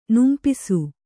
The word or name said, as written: ನುಂಪಿಸು